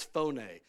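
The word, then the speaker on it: phone (φωνή): It's, and it's not, in the Greek, it's not pronounced phone, it's phone. There's a little accent on the E.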